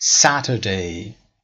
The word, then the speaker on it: Saturday: تلفظ Saturday. این علامتی که میبینی ترکیب حرف A و E هست، صدای ا میده، مثل کلمه cat. Pat. پس این علامت رو هم یاد گرفتیم. پس Saturday. آیا سریعتر میتونید بگید Saturday؟ یعنی آر وسط رو تلفظ نکنید. استرس همچنان قسمت اول.